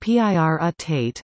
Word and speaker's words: pyrrhotite: Pyrrhotite, pronounced P-I-R-U-T-A-T-E, is an iron sulfide material containing low levels of nickel, cobalt and copper.